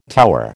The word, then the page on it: tower: American English